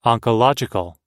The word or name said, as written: oncological